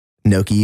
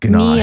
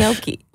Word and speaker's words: gnocchi: How do you say gnocchi? – Gnocchi. – Because the waiter always goes, how does he say it? – I would have said ganache. – Gnocchi.